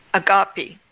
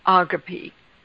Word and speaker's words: agape: If we have not agape, and that's how it's pronounced in the Greek. I know in America we pronounce it agape. But in the Greek, the right pronunciation is agape.